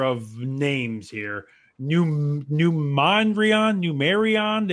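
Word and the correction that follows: Numenorean: their Adore album. Numenorian. See, I told you I will butcher it.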